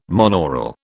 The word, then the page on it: monaural: British English